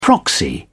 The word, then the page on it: proxy: British English